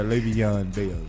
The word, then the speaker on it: Le'Veon Bell: Yeah, Le'Veon Bell.